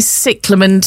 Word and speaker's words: cyclamen: It is definitely cyclamen.